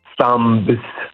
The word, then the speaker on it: thumbs: noi diciamo thumbs. Ok, quindi comunque la B non la pronunciamo ed è sempre lo stesso criterio, la M che poi è seguita da B.